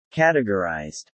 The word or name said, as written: categorized